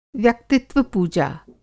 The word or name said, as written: व्यक्तित्व-पूजा